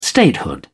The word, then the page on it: statehood: British English